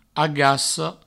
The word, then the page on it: agaca: agasso